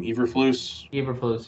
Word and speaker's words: Eberflus: Although Matt Eberflus, I don't know how to pronounce his last name. Eberflus, yep.